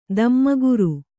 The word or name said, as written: ಧೂಮಕರ